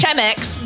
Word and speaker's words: Chemex: It is spelled C-H-E-M-E-X. – Me. – It's Chemex, but... – No. Chemex. – That is the correct pronunciation.